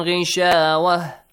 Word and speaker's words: غشاوة: Make sure you do not pronounce that has so kind of clearly. Rishawah. No, don't need to release breath, okay? For example, this way. – وَعَلَىٰ أَبْصَارِهِمْ غِشَاوَةٌ. – Simple, not long has sound, okay? – صَدَقَ اللَّهُ الْعَلِيُّ الْعَظِيمُ.